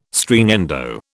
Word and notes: Stringendo: British English

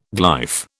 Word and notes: glyph: British English

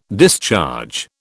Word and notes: discharge: British English

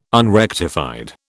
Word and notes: unrectified: British English

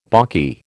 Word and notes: balky: American English

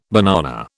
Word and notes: banana: British English

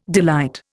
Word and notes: delight: Indian

